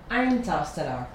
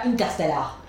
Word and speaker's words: Interstellar: On dit Interstellar. – Ah non! – On dit pas Interstellar. – Interstellar. – On dit Interstellar.